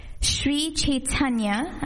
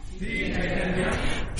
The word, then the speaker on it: Sri Chaitanya: In India, about 500 years ago, today was the day where Sri Chaitanya, I'm going to have you say his name, Sri Chaitanya. – Sri Chaitanya.